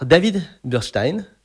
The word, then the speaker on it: David Bursztein: Alors, David Burstein, je prononce bien? – Bravo.